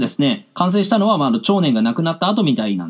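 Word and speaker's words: 奝然: 長年というのは少し難しい字でございまして、長というのは大きいという字の下にですね、なんか、一周して田中みな実好きだわ、の、周という字を書いて、長と読むんですね。そして、宇垣美里とか、全然好きじゃないんですけど、の禅。 2 回出てきましたね。大自然の禅ですね。はい。これで長年と読みます。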